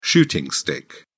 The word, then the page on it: shooting stick: British English